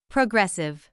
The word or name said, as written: progressive